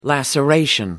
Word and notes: laceration: American English

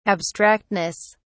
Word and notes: abstractness: British English

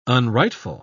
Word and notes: unrightful: British English

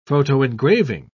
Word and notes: photoengraving: British English

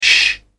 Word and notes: sh: British English

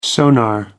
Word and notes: sonar: American English

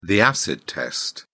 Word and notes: acid test: British English

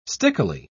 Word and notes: stickily: British English

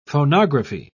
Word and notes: phonography: British English

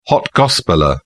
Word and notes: hot gospeller: British English

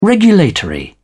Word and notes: regulatory: British English

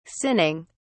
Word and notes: sinning: British English

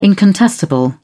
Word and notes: incontestable: British English

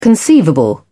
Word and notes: conceivable: British English

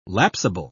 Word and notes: lapsable: British English

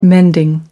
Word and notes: mending: American English

